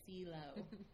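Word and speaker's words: CILO: And the way you would pronounce the acronym is CELO.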